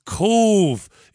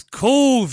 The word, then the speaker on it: Cobh: And I said, it's Cove. It's Cove, you mad American bastard. Cove, C-O-B-H. We pronounce B-H as a V in Ireland.